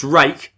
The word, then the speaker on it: Rake: He said, just listened to the podcast and realised I got to mention it's pronounced rake as in the garden tool, in case you were wondering.